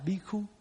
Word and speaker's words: Bhikkhu: Mà Nam Phạn thì đọc là Bhikṣu, mà Bắc Phạn đọc là Bhikkhu.